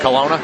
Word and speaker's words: Kelowna: I'm talking with Brendan Ellis from Kelowna, British Columbia, and first of all, I pronounced that right, at Kelowna? – Yeah, absolutely.